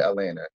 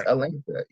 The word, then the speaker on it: Atlanta: I pronounce the T in Atlanta. I don't call it Atlanta. It's Atlanta. You know what I'm saying?